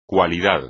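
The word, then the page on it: cualidad: español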